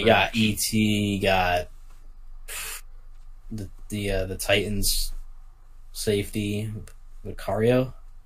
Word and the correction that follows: Vaccaro: Vaccaro, yeah.